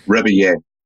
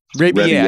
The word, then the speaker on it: Rebillet: Mark Rebillet. – Rebillet.